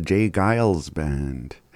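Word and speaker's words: J. Geils Band: That was Freeze Frame by the Jay Giles Band.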